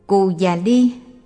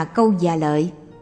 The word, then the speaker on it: Cù Già Ly: Cù Già Ly, tên một vị tỳ kheo, được phiên âm từ Phạn ngữ là Cô Ca Đi Ca, cũng đọc là Câu Già Lợi, là đệ tử đi theo Đề Bà Đạt Đa.